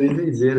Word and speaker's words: Isensee: é, cara, é Vitor Izenzê, né, meu, sobrenome. Que na verdade não se escreve com Z, né? Se escreve com S. É, o Z é mais para esse, pra esse trampo solo mesmo, que então eu quis fazer isso pra, até para forçar a pronúncia, né? – Geralmente é é licença.